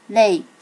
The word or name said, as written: rei